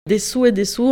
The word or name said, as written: Dessous dessus